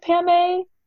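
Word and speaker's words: Pamay: And so she gave me a nickname that sounded like Pam A.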